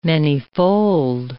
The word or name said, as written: manyfold